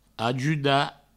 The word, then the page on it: ajudar: ajuda